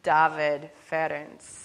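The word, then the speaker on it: Dávid Ferenc: This saying, credited to the 16th century Unitarian Francis David, or how would I say it if I said it correctly? David Ferenc.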